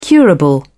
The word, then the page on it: curable: British English